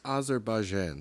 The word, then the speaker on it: Azerbaijan: It's Azerbaijan.